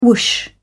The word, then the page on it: whoosh: British English